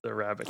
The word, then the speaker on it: Arabica: And I'm going to butcher this as like Arabic. Arabica? I don't know. – Yeah. – Arabica. – It's Arabica.